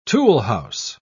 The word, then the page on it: toolhouse: British English